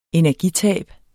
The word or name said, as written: energitab